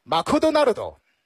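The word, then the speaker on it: マクドナルド: 如果是日式英语的话那就是 Makudonaldo，听上去有点像马上就拉裤兜是吧。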